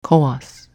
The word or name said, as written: Cowass